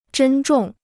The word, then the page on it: 珍重: zhēn zhòng